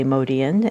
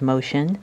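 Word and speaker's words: Motian: Someone noticed that I always say Modian, and they knew him as Motion. And I will say that both pronunciations are correct, in that I sort of debated which one to use for the show, because I think a lot of his fans know him as Motion. But Modian is sort of the real pronunciation that I grew up.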